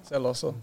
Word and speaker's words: celoso: Celoso? Celoso? How do you say that? – Celoso.